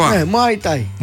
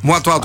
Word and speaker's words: Muay Thai: Muay Thai. – Muay Thai, το πείσαν την εταιρεία με τα ρούχα.